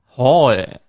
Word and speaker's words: Haul: H-A-U-L. – Howl. – Hall.